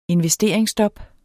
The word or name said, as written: investeringsstop